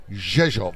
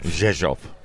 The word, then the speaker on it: Rzeszów: If you just read it, because it starts with an R, but you never hear the R. And we've been reliably informed by locals that it's pronounced Rzeszów. – Rzeszów.